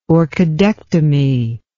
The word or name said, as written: orchidectomy